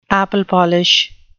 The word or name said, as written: apple-polish